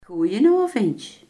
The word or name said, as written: goedenavond